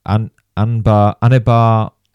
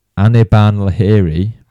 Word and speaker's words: Anirban Lahiri: And seemingly he ran away with it because, I don't want to absolutely butcher this, Anirban Lahiri. I've said that wrong. – Anirban Lahiri.